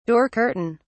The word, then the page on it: door curtain: British English